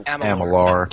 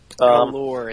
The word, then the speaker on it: Amalur: Amalur. – Amalur. – Amalur. It's a U at the end.